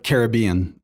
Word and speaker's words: Caribbean: it's pronounced it's Caribbean. Every time they say it to right. me, I'll be, it's pronounced Caribbean. – It's Caribbean.